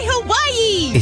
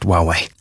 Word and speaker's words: Huawei: And set up my Hawaii. – It's pronounced Huawei.